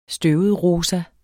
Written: støvetrosa

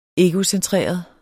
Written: egocentreret

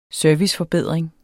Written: serviceforbedring

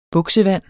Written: buksevand

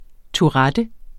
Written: tourettes

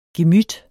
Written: gemyt

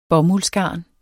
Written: bomuldsgarn

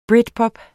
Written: britpop

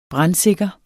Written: brandsikre

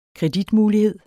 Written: kreditmulighed

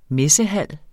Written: messehal

